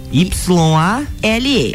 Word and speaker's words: Yale: Yale, Y A L E. Y A L E. L E. – Yale. Olha aí veja só. como é que fala é Yale.